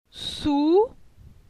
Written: Sou

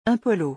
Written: un polo

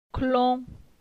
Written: Clon

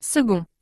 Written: 2d

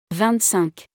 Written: 25

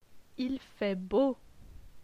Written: Il fait beau

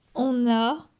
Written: on a

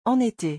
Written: en été

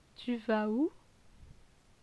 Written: Tu vas ou

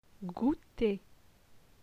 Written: Gouter

